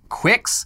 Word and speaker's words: QUX: It's it's spelled Q U X. Cox. And uh, the promotional materials on its website pronounce it like Quicks.